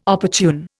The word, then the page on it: opportune: Indian